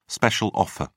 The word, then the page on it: special offer: British English